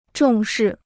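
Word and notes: 重返: chóng fǎn

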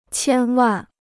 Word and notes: 千万: qiān wàn

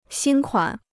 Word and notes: 新款: xīn kuǎn

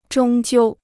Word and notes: 终究: zhōng jiū

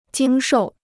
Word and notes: 经受: jīng shòu

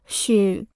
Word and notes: 续: xù

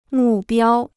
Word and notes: 目标: mù biāo

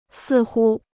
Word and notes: 似乎: sì hū

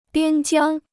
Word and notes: 边疆: biān jiāng

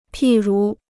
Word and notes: 譬如: pì rú

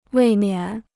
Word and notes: 未免: wèi miǎn

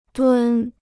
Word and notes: 蹲: dūn

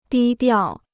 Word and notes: 低调: dī diào

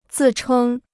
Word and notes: 自称: zì chēng

